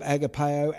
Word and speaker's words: agape: Our English word love that's translated in that passage is translated from the Greek verb agapeo. We're familiar with both these words, I guess, the Greek verb agapeo and the noun agape, or as some pronounce it, agape.